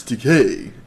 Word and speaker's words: Takei: It's Takei. It sounds like toupee.